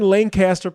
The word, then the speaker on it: Lancaster: We're in Lancaster, Pennsylvania. – Close. Close. – Oh, shoot. – We pronounce it Lancaster. You got to say it like you're sneezing. – We're in Lancaster.